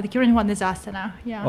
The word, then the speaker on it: Astana: Astana.